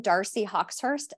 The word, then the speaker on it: Darci Hawxhurst: I'm on Facebook and Instagram just under my name, Darci Hawxhurst. And should I spell that? Yeah, it's kind of a doozy. So it's DarciHawxhurst.